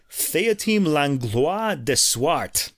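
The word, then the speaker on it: Théotime Langlois de Swarte: So Thomas Dunford, and this guy, the magnificently named violinist Théotime Langlois de Swart.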